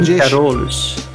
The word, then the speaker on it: Gouden Carolus: Gouden Carolus.